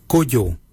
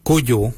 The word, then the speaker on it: Goodjal: Ken is spelled G-E-N-Y, Ken. Number 2, we say Kujo. Kujo for number 2. Kujo is spelled G-O-O-D-J-A-L.